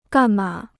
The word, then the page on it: 干吗: gàn má